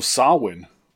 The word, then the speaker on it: Samhain: All right, the tradition originated with the ancient Celtic festival of Samhain, spelled S-A-M-H-A-I-N, and it's pronounced Samhain. – It's pronounced Samhain, but yes, it's spelled Samhain.